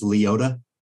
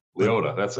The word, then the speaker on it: Liotta: I've always pronounced it as Liotta. – Liotta, that's probably right.